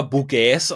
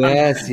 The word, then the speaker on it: Bouygues: Das schreibt man B-O-U-Y und dann GES, also G-U-E-S. Ah. Also ich hätte es wahrscheinlich eher Bouygues ausgesprochen, aber es gibt auf YouTube ein eigenes Video, wo man lernen kann, Bouygues, oder Bouygues ausgesprochen werden.